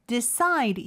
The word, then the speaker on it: decide: 그 다음에 decide 이음절의 강세가 있고요.